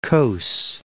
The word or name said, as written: Causses